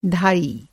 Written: धाई

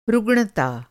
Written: रुग्णता

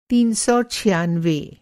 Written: तीन सौ छियानवे

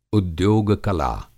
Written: उद्योग-कला

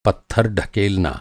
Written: ढकेलना

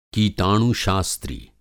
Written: कीटाणु-शास्त्री

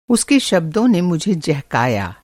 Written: जहकना

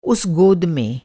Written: गोद

